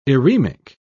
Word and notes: eremic: British English

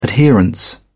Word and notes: adherence: British English

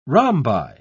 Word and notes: rhombi: British English

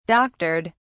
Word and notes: doctored: British English

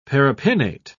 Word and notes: paripinnate: British English

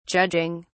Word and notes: judging: British English

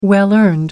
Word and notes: well earned: American English

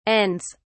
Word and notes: ends: British English

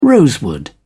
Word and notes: rosewood: British English

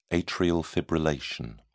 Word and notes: atrial fibrillation: British English

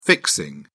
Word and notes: fixing: British English

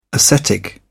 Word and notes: ascetic: British English

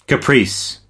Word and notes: caprice: American English

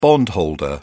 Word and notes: bondholder: British English

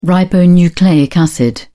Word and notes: ribonucleic acid: British English

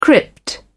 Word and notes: crypt: British English

